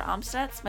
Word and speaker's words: Amstutz: You can find me on all social platforms at Sandra Amstutz.